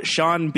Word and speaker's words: Sean: Did you ever notice how Sean Bean's first name really should rhyme with his last, but it doesn't? – Never.